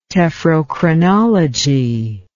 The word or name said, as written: tephrochronology